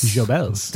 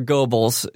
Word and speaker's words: Goebbels: Or Goebbels is how it's said in proper English.